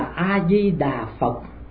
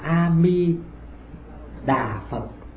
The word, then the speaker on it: A Di Đà Phật: chữ A trong danh hiệu Đức Phật A Di Đà phải đọc là A chứ không đọc là Ơ.